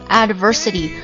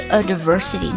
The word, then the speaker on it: adversity: 我老是不知道这个单词应该怎么读我经常会说 advertisement 有广告那个单词我会说 adversity 其实不是应该是 adversity